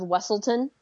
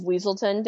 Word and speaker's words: Weselton: Yeah, in Frozen, the bad guy is one of the bad guys, kind of bad guy, is the Duke of Weselton, and they call him the Duke of Weaselton. They say it wrong.